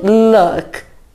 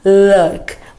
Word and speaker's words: luck: Então você vai falar com a Teacher aqui, ó, bem bonito, good luck, luck, ó, look. Porque good look é diferente.